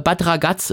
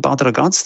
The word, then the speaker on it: Bad Ragaz: Das ist dieselbe Ausfahrt, wo auch Bad Ragaz ist, ne? Das ist doch, habe ich das jetzt richtig ausgesprochen oder heißt das Bad Ragaz? – Nein, du hast echt Talent.